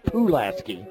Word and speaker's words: Pulaski: I said Pulaski, and it's Pulaski.